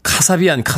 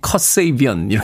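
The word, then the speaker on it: Kasabian: 카사비안, 카사비안 이렇게 읽었었는데 발음교로 예, 한번 찾아봤더니 컷세이비언 이렇게 읽어야 된다고 하는군요.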